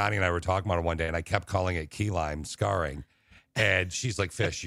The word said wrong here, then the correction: keloid: It's keloid, like bubonic plague.